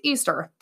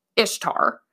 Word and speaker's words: Ishtar: And it's like Ishtar is actually pronounced Easter. That's wrong. Ishtar is pronounced Ishtar.